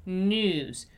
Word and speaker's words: news: It's news, news, news, like a long oo sound, right?